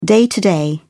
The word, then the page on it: day-to-day: British English